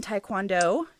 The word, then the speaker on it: Taekwondo: She is currently a second degree black belt in Taekwondo.